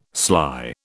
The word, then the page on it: sly: British English